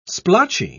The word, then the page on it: splotchy: British English